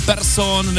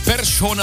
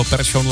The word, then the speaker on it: Persson: Místo Persson nebo Larsson jsme slyšeli Persson a Larsson a fanoušci se ptají, co to znamená. To je nějaká změna? Proč se to děje? My to víme, protože právě takhle se to ve švédštině opravdu vyslovuje. Respektive vyšlovuje. Takže my jsme Persson a Larsson celé ty roky říkali špatně. Správně je to Persson, Larsson.